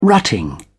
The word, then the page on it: rutting: British English